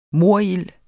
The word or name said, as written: morild